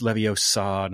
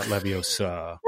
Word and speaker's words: Leviosa: And so they'll go, well, it's Leviosa, not Leviosa, or something like that from Harry Potter.